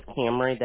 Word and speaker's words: Camry: My name is Camry.